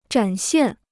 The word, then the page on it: 展现: zhǎn xiàn